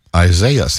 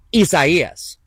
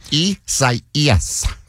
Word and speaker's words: Isaias: And we thought it would have been pronounced like this. – Isaiah's. – That's how we were saying it yesterday. – That's what a computer thinks as well. But a foreigner says, this storm formed this morning. Isaias.